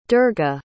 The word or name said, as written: durga